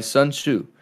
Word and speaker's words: Sun Tzu: Ronan asks, a while back, Fruit said he was reading The Art of War by Sun Tzu.